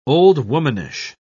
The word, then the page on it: old-womanish: British English